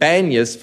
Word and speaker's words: Banyas: But the Arabs had difficulty pronouncing P's, and they said Banyas for Panias. And so the name has come to be Banyas now of this area that was once Caesarea Philippi.